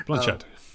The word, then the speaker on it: Blanchett: I can't even pronounce her last name. – Blanchett.